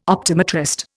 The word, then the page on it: optometrist: Indian